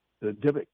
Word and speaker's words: Dybbuk: Well, there's another word in your book that appears quite frequently, and is the correct pronunciation Dybbuk? – The Dybbuk, yeah.